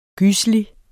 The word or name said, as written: gyselig